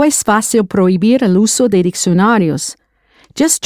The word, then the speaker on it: prohibir: Prohibir, spelled P-R-O-H-I-B-I-R, that silent H again.